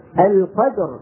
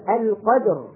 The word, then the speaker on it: القدر: لكن شوفوا مثلا كلمة القدر، هذه فيها صعوبة. يا رفيق أبدًا القدر، لما نقول القدر هذه فيها صعوبة، لأنه فيها جمع بين الساكنين والأول منهما مخلقل. القدر فيها صعوبة.